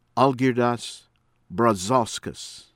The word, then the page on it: ABISALA, ALEKSANDRAS: ah-lehk- SAHN -drahs ah- BIH -shah-lah